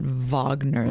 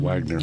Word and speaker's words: Wagner: You probably want to pronounce that Wagner, though. Mm-hmm. – Yes, not mm-hmm. Wagner.